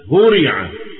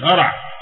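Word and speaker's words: هُرِع: أشهرها طبعا فعل مشهور جدا هورع، هورع مفيش هرع، هورع يهرعون، يو مش يهرعون يهرعون، فعل هورع ده فعل مبني المجهول.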